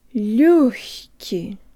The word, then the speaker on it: лёгкий: Just make sure your tongue is high before you start pronouncing the г or the х sound in хки. Джо танг липс. Лё, хки. Лёгкий.